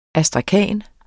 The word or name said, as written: astrakan